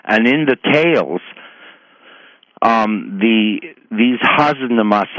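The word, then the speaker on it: Hasnamuss: What he means by this, and I don't know the derivation of this term husnamus. It's spelt H-A-S, like has, na, N-A-M-U-S-S, husnamus.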